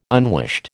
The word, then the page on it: unwished: American English